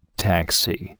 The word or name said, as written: taxi